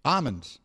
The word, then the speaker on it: almonds: Almonds. That's correct. It's almonds. – He said an L. – He didn't say an L.